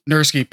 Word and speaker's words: Nurski: Nurski, perfect.